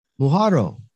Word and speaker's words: Muhorro: Muhorro.